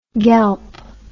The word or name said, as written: gelp